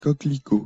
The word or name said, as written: coquelicot